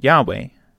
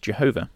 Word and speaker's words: Yahweh: "I appeared," said he, "to Abraham, Isaac, and Jacob by the name El Shaddai, but by my name Yahweh," commonly but erroneously pronounced Jehovah, "was I not known unto them."